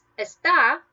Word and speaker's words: está: and that means that you stress that last syllable, the A, está.